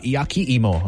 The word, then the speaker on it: yakiimo: Well, I'm talking about yakiimo.